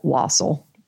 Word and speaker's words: Wassel: Uh, okay, somebody uh, recently discovered they had a great uncle Wassel, pronounced like waffle.